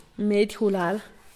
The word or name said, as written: medygulal